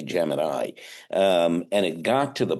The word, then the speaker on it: Gemini: Gemini, but they weren't really familiar with the pronunciation.